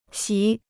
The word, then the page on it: 席: xí